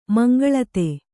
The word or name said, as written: ಮೀಂಗುಲಿತಿ